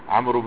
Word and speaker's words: عمرو: عمرو بن العاص نه دی ته دوی ولاړي چې دا اوس څه ټکی دی ځکه دا وو باندې خو تلفظ کېږي نه